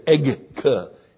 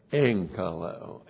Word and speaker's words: enkaleo: Even though that's a gamma, you pronounce it as an N. Because it's very hard to say egg-ka-leo. That's very hard to say. So that's enkaleo. E-N-K-A-L-E long O.